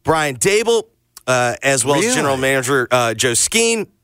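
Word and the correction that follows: Schoen: How do we say it? – Shane.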